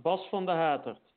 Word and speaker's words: Bas van de Haterd: How do you say it? – Close enough. Bas van der Haterd.